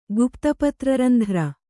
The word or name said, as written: ಗುಪ್ತಪತ್ರರಂಧ್ರ